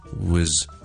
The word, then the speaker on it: was: 但是最正常的 was 这个字的发音呢其实呢是 was。 There are two important things to remember，两样嘢要特别留意。 Number one，嗰个 vowel 系一个超级极之弱嘅响音，系一个咁所以咧就咁讲咧，w 就可以啦。